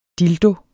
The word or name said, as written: dildo